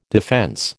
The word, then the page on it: defence: American English